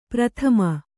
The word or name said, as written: ಪ್ರಥಮೆ